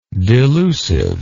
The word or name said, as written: delusiveness